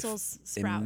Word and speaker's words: Brussels sprouts: And I'd like to point out that they are called Brussels sprouts. – Yeah, there's an S there. – There's an S, and I've been saying it wrong my whole life. – Brussels sprouts.